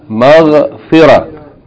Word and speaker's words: مغفرة: if we stop on this word we pronounce it مَغْفِرَة يعني the ta marbuta is pronounced like ha with no voweling مَغْفِرَة مَغْفِرَة مَغْفِرَة with if we keep reading we say مَغْفِرَةٌ مَغْفِرَةٌ